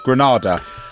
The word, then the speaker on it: Granada: correct me if I've got the pronunciation wrong, Granada or Grenada? Granada. – Granada is a Caribbean island. Island, yes. – Granada is España.